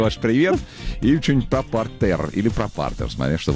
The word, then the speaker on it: партер: Когда мы говорим про спорт, это партер.